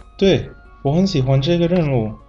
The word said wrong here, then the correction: 人物: 艾莎公主是这部电动画片里的人物，人二声，不是任务。 – 哦，二声人物。